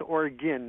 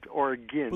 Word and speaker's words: Oregon: Yeah, it's pronounced Oregon, not like argon gas, but Oregon.